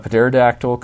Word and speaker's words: pterodactyl: A pterodactyl comes in to land. Pterodactyl, sorry, the P is silent.